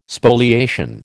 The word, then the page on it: Spoliation: American English